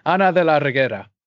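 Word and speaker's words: Reguera: I've got much more ideas, but we have Dave Bautista in here, Ella Purnell, Omari Hardwick, Ana de la Rugra. Damn, I don't think I said that right. Regrugra? Damn it. I don't know. Theo Rossi. – Yeah, I think it's Regera.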